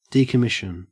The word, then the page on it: decommission: Australian English